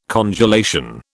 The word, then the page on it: congelation: British English